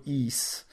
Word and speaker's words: Ys: De most furcsa, furcsa módon azt úgy kell kiejteni, hogy íz, Tehát egy YS.